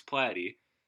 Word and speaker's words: Platte: What I recently found out is that you can just Google my name, Chris Platy, and that's Platy is spelled P-L-A-T-T-E, and you could just Google search me, and my podcast and my Twitter and my Facebook will all come up.